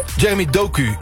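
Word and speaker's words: Jérémy Doku: Jeremy Doku, ja.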